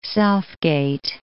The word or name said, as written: South Gate